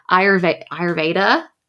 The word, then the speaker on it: Ayurveda: So you were talking about like imbalances in the body and addressing that with, is it, I'm going to say it wrong, Ayurveda? – Yeah. – That's yeah, it. Tell us about this. What is that? All yeah. – of that. – So Ayurveda has been around for about 5,000 years.